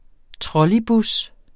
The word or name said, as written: trolleybus